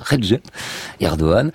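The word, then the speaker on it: Recep Erdoğan: Voilà, ça s'écrit Recep Erdogan, il faut dire Recep Erdogan, est déjà un peu tendu depuis 2 ans, parce qu'il y a eu les printemps arabes.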